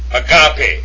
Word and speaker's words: agape: The next one that's popular to us is agape.